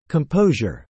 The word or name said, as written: composure